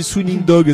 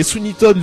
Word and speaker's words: Sweeney Todd: Je remets, mais oui, toi tu disais Sweeney Dog, c'était marrant. Ouais. c'est Sweeney Todd, le diabolique barbier de Fleet Street.